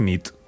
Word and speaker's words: nit: Y noche es nit. – Muy bien. – Buena nit. – Buena nit, es verdad. – Buena nit.